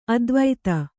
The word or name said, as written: ಅತಿವಾದಿ